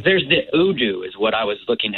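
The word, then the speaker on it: UDOO: It's U-D-O-O. – Udu.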